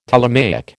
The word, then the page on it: Ptolemaic: American English